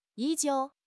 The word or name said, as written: 依旧